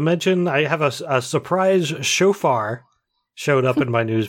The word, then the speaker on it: shofar: No, it's shofar.